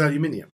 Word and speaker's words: aluminium: Was it made of aluminium? Or whatever however he pronounces it. – Aluminium. – Aluminium. – And no, he says aluminium. – Does he say it properly? – Yeah.